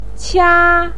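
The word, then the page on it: qia1: tone 1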